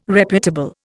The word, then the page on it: reputable: Indian